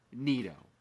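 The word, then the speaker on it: Nido: Because you can't have if McCann gets hurt again, you can't have a combo of Mazzica and Nitto. Is that saying it right? – Yeah, no, you got it.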